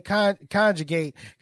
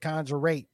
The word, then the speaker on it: conjugate: It's funny you say conjugate because I believe Herschel Walker would pronounce it conjurate.